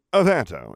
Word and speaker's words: Averto: Now, using the exact same wand movements, cast the spell again, only saying Averto instead of Protego. Averto instead of Protego. The accent is on the second syllable, which is pronounced to rhyme with air with a slight trill in the R.